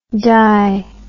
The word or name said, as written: dy